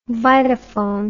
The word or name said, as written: vitaphone